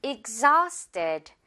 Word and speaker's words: exhausted: 일단 앞부분은 익 하고 z, 이제 Z 발음 준비하고요. 이 H는 지금 무음이에요. 발음 나지 않아서 바로 또 모음으로 연결해주면 돼요. Exhausted.